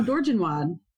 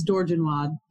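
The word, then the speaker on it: Dorgenois: No, Dorgenwad. That's how they say it in New Orleans. I can't even say that. Google where? – Told me it's Dorgenwad.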